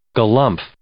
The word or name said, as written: galumph